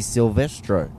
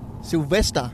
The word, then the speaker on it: Silvestro: You've got to say her name correctly now. Come on. Simone Di Silvestro. – Sylvester. – Silvestro.